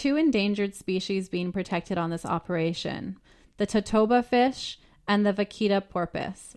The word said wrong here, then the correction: totoaba: Roy, am I saying that right? – Totoaba. – Totoaba, that sounds much nicer.